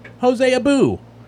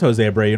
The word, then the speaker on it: Jose Abreu: pronounced it Jose Abu, which that has never been how you pronounce Jose Abreu.